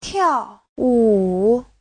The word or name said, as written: tiao4wu3